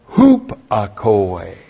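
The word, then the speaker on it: Hupakoe (ὑπακοή): And then, hupakoe. So here's the contrast from parakoe to hupakoe. H-U-P-A-K-O long E. Hupakoe.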